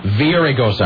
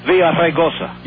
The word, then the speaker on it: Villaraigosa: Villaraigosa. – I didn't know if the G was silent or not. – Or as he says, Villaraigosa.